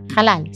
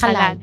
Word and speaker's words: halal: Halal, carne halal.